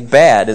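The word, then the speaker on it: bad (בד): It's the word, ironically, bad is how you pronounce the Hebrew word. Bad is the Hebrew word alone. But it has two possible meanings in this context.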